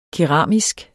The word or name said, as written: keramisk